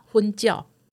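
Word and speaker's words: 粉鳥: 但事实上，事实上，鸽子的台语发音叫什么？ – 什么？ – 昏叫，听过吗？ – 哦，我以为鸟都叫昏叫。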